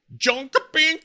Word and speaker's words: Jönköping: It's happening in Jönköping, Sweden. – Nice pronunciation. I'm surprised you got it right.